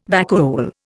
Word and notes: vacuole: Indian

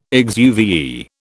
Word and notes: exuviae: British English

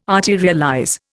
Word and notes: arterialize: Indian

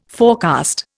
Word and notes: forecast: Indian

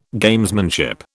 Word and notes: Gamesmanship: British English

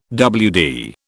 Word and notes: WD: British English

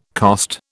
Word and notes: cost: British English

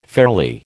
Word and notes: fairly: American English

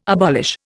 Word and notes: abolish: Indian

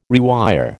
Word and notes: rewire: American English

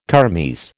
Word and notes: kermes: American English

